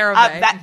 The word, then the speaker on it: CeraVe: We love CeraVe? Is that how it's pronounced? – Yep, CeraVe.